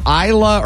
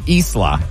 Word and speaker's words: Isla: B-A-R-T-H-Y? Cannon? I like a cannon. And I don't know, I never know if it's Isla or Isla. I-S-L-A. I guess it's both of those pronunciations, but it's very popular.